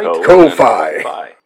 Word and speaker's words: Ko-fi: That's and then you put the dash in there, so you have to emphasize the syllables. – Right. – Ko-Fi.